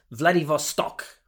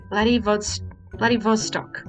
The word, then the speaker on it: Vladivostok: Hang on, I'm looking it up. Vladivostok. Vostok. That's what it is. Vladivostok. – Yeah, Vladivostok. Yeah, I can say it now. – That's it. – Vladivostok. – Yep, that's it. That's how you say it.